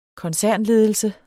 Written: KONCERNLEDELSER

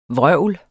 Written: vrøvlet

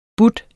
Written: byde

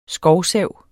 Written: skovsav

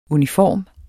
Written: uniform

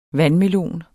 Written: vandmelon

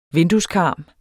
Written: VINDUESKARM

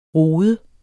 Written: rode

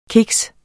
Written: kiks

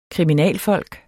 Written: kriminalfolk

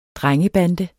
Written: drengebande

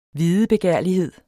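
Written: videbegærlighed

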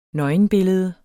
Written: nøgenbillede

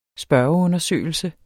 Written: spørgeundersøgelse

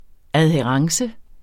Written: adhærence

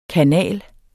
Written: kanal

